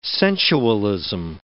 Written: sensualism

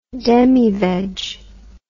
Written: demiveg

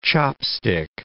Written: chopstick

